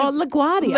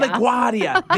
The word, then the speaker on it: LaGuardia: Well, over in the United States of America, at one of the big international airports, I can't pronounce it, El Grande, in New York City. – Oh, LaGuardia? – I think LaGuardia. – LaGuardia. There we go.